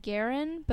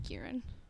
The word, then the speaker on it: Guerin: It's um Irish, so my grandparents said Guerin, but you can't say that in an American accent. Really? So now it's just Garen.